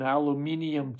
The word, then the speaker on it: aluminium: I'm about to get on an aluminium tube. That's how they say it down there. An aluminum tube at 40,000 feet over the Pacific Ocean for a 2-month tour of Australia, 51 performances in 60 days.